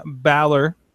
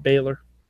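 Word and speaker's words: Bálor: It is Finn Balor. – We haven't all been through this. – We have all been through this. – I have never pronounced it Baylor.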